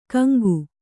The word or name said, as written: ಗೆಂಕು